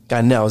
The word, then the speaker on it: Gaynell: So everybody, welcome in our girl, Christy Gaynell. Is that how you say it correctly? – Gaynell, that was close enough. – All right, I'm gonna put some Atlanta on it. Gaynell.